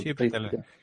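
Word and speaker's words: Chiwetel: Mä, itse asiassa mä, mä just mietin kanssa, tota, että miten tuon kaverin nimi lausutaan ennen tätä podcastia. Mm. Shivetel Si- Sitähän on britti eikä mikään ranskalainen. – Joo, ihan, si- ihan Sivetel ei joi for.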